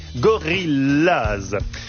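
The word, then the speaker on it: Gorillas: Gorillaz se prononce comme en anglais. Gorillaz.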